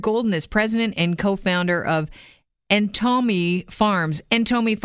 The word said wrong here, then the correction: Entomo: Farms. Am I saying that correctly? – Entomo. – Entomo. Entomo Farms. – You got it.